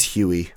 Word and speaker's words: Hui: It's H-U-I. Man, it's terrible. I can't. It's awful. I can't do this. I need to know the exact pronunciation. – Yeah. Anyway, I'm probably saying it wrong. – It's Huey. – Huey? – Huey. Chamberlain Huey.